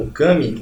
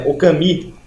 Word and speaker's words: Okami: O jogo é chamado Okami, ou na pronúncia mais correta, pelo que eu li, que não falo japonês, é Okami.